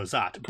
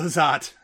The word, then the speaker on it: Szot: I don't remember when it was or who said it, but someone said something like, pro tip, Paolo S-Z-O-T rhymes with hot. So I don't know if that was as much a pronunciation tip or just a fact about the attractiveness of Paolo Zott.